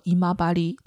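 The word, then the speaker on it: 今治: 精致在日语当中是读作姨妈巴黎，它的毛巾就是姨妈巴黎套路。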